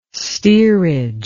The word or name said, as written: steerage